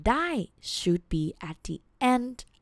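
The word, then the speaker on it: Die (ได้): The first word, the word that you already know, the word die. Well, it's supposed to be a short vowel sound, but Thai people pronounce it with a long vowel sound, with a falling tone. Die.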